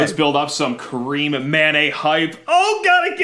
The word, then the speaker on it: Karim: It's Kareem is spelled K-A-R-I-M, so that's pretty easy. We got that Kareem, and then we think it's Mané. It's M-A-N-E. So we're going with Mané.